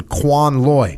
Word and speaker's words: Quan Loi: On April 16th, 1969, I was flying with Mr. Driscoll, returning from a long day in Quan Loi.